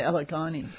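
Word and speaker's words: Alyogyne: A native hibiscus, can somebody help me with the pronunciation of this thing? – I say allegheny. – Yes. – That's more or less, yes.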